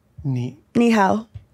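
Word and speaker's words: Ni >> hao (你好): Ni hao.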